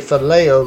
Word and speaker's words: phileo: There is phileo love, which is spelled P-H-I-L-O-S, but we say phileo love.